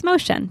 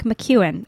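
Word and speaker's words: Mociun: The next person is Caitlin Motion, and I pronounced her last name completely incorrectly for about 4 years, and it's Motion, but it looks like McEwen. It's M-O-C-I-U-N.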